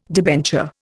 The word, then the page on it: debenture: Indian